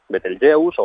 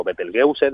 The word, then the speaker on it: Betelgeuse: Betelgeuse o Betelgeuse, depende de cómo se quiera pronunciar.